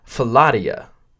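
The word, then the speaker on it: Philautia: And by comparison, there's, I had to look up how to say this because it looks like another word. Philatia. It looks like fellatio.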